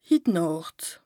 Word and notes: hit Nàcht, hinnicht: Bas Rhin

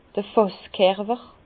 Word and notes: d'r Fàsskerwer: Bas-Rhin; Herrlisheim

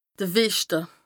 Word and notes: de Weschte: Bas-Rhin; Strasbourg

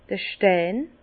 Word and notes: d'r Stein: Bas Rhin; Herrlisheim

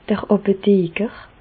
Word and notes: d'r Àpotheker: Bas Rhin; Reichshoffen

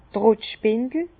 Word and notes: d' Trottspindel: Bas-Rhin; Herrlisheim

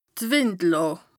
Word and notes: Wìndlàà: Bas Rhin

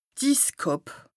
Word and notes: d' Isskàpp: Bas-Rhin; Strasbourg

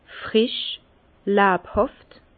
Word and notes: frisch, lebhàft: Bas-Rhin; Herrlisheim

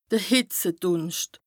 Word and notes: de Hìtzedùnscht: Bas-Rhin; Strasbourg

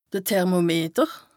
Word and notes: de Thermometer: Bas Rhin; Strasbourg

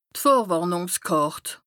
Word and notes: d' Vorwàrnùngskàrt: Bas-Rhin; Strasbourg

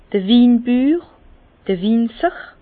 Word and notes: d'r Winbüür, Winzer: Bas Rhin; Herrlisheim

